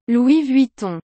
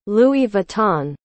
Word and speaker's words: Louis Vuitton: 路易威登读作 Louis Vuitton，不是 Louis Vuitton，是 Louis Vuitton，Louis Vuitton，Louis Vuitton，很重要，所以要说三遍。